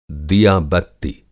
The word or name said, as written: दिया-बत्ति